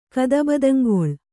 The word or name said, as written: ಕದಬದಂಗೊಳ್